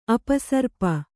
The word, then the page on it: ಅಪಸರ್ಪ: apasarpa; kannada